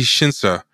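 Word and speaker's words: Shinsha: The Spider-Man animated series had this Japanese studio called Tokyo Movie, or I forget how to pronounce it, Tokyo Movie Shinseida. Let me get the name right. It's Tokyo Movie Shinsa.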